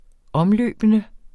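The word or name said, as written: omløbende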